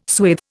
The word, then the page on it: swathe: Indian